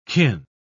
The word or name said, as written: kin